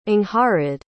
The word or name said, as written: angharad